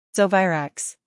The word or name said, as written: Zovirax